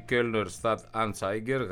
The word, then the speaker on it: Kölner Stadt-Anzeiger: Να πάμε και στη Γερμανία, όπου η Kölner Stadt-Anzeiger γράφει ότι η αυστηροποίηση του μέτρου...